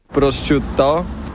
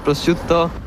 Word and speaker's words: prosciutto: Prosciutto, prosciutto, prosciutto.